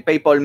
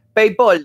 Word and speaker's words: PayPal: PayPal, dice. Se ríe de que pronuncia PayPal. ¿Y cómo se dice? PayPal. PayPal. PayPal. La, A se pronuncia como una O.